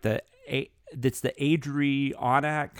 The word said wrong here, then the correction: Adirondack: How do you know what I'm talking about? The mountains, the Adirondack. – Adirondack.